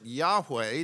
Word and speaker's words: Yahweh: we pronounce it Yahweh. Do you know that term? Yahweh, four letters in the Hebrew called the Tetragrammaton.